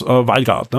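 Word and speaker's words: Veilguard: wie spricht man es aus? Valgard, ne? Ja.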